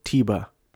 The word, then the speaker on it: Teba: But I cheered when they showed Teba, which I've been pronouncing Tebow. Not Tebow, Teba. Tim Tebow. Teba. But it's pronounced Teba.